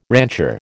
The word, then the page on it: rancher: American English